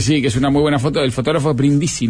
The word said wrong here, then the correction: Brindicci: ¿no? – Brindicci. – Brindicci se llamaba. – Sí, porque se escribe con doble C.